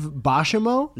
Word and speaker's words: Buscemi: Steve Buscemi? – Not even kidding. That's how you say it.